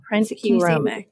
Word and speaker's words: Prentke Romich: Okay, so one thing, Prentke Romich. Am I saying that right or wrong? Yep, Prentke Romich.